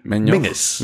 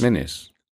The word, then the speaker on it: Menzies: So you have this name Menzies, which is actually Mingus. – Mingus. – Okay. Mingus is how it's pronounced.